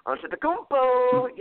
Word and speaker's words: Antetokounmpo: Giannis Antetokounmpo recently said in an interview, I have to say it like that.